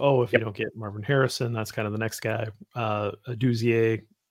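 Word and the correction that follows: Odunze: Is that how you say it? – Adunze. – Adunze. Adunze.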